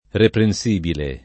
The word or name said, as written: reprensibile